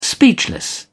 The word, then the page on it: speechless: British English